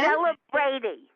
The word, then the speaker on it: CelebrEighty: C-E-L-E-B-R and then big E-I-G-H-T-Y. – How do I pronounce it? Say it again. – Celebrity.